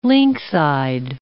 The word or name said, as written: lynx-eyed